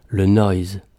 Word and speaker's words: Lanois: Cet album, Neil choisit de l'intituler « Le Noise », alliage d'un article français et d'un substantif anglais, mais aussi hommage appuyé au travail de son compatriote Lanois, qui en anglais se prononce « le noise ».